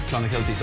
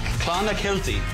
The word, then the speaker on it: Clonakilty: Irish tea bags or Clonakilty sausages from Cork. – Clonakilty. – Clonakilty.